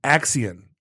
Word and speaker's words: Accion: Delivery of Axion Labs, Axion. – Axion, yes. – A-C-C-I-O-N.